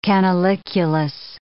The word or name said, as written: canaliculus